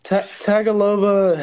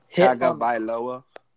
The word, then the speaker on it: Tagovailoa: Tagovailoa has a quad injury. – Tagovailoa? – Yes.